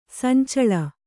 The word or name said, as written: ಸಂಚಳ